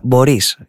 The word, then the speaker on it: Μπορίς: Σαν σήμερα το 1960 φεύγει από τη ζωή ο Μπόρις Πάστερνακ, Μπορίς, για την ακρίβεια Μπορίς είναι το όνομα, εκεί τονίζεται στα ρωσικά, Ρώσος συγγραφέας, ο οποίος τιμήθηκε με το βραβείο Νόμπελ Λογοτεχνίας το 1958 για το έργο του Dr. Zhivago.